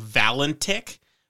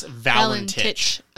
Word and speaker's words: Valentich: And I was about to read it the way I wanted to because it spelled a little differently than that because it spelled like Valentich, but it's Valentich.